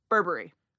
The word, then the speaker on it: Burberry: Burberry, it's Burberry. – Burberry. – So you kind of rush past it.